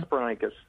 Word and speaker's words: Hesperonychus: Hesperonychus.